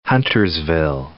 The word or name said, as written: Huntersville